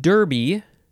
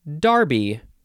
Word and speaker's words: derby: The next word is derby, and the British say darby. Is that, let's just confirm the pronunciation. I believe that is correct. Derby. It's just D-E-R-B-Y.